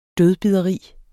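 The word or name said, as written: DØDBIDERI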